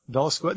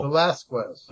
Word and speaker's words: Velasquez: Did I say that right? Velasquez? No. – Velasquez. – Velasquez.